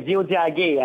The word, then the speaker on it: Tiohtià:ké: oui, Diodagé, avec, euh, en fait, comme je disais à quelqu'un hier, c'est, c'est des T et des, euh, K, mais il faut prononcer des D et des G.